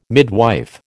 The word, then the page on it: midwife: American English